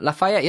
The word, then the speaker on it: Lafayette: How do you pronounce that? Lafayette, Louisiana? – Lafayette, yeah. Beautiful.